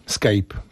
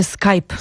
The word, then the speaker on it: Skype: Scape. Po raz kolejny. – Właśnie ktoś nas zapytał, czy słowo scape ma cokolwiek wspólnego z prawdą. Otóż nie ma. – Nie. – Mówi się Skype.